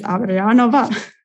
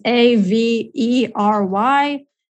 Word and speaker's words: Averyanova: And yeah, so the best way to reach me is on my Instagram, Elizabeth Averyanova. – You might want to spell that. Yeah, you might want to spell that too. – So Elizabeth and then last name is Averyanova.